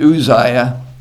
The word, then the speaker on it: Uzziah: So I looked it up. I guess I'm going to pronounce it Uzziah. I'll probably forget. I wasn't used to pronouncing it quite that way.